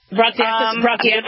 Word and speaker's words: bronchiectasis: You have what's showing up more and more is this thing called bronchiectasis. Is that, am I pronouncing it well? – Yep, bronchiectasis.